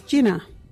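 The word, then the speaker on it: jina: Our local Wadjuri word is jinna.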